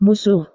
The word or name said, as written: musuh